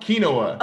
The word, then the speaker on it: quinoa: Quinoa.